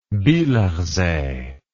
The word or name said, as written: bielersee